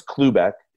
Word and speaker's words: Klubeck: my my last name's Klubeck.